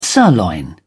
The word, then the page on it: sirloin steak: British English